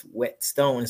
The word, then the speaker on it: Whetstone: And I tell them my last name is Whetstone. So they think it's W-E-T. And I'm like, no, the H is silent.